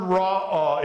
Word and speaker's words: ra'ah: It's the word ra'ah. It's R-A-A-H in Hebrew, if we could put that into English. Ra'ah.